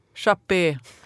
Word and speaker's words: Chappé: Och en sån kista var nog det som intresserade mig mest på invigningen av det nya konstmuseet Chappé, eller Chapp, hur uttalar man det där? – Hur ska det vara på finska? Ja. Chappé. – Chappé, här i Ekenäs, Finland.